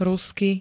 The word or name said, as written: rusky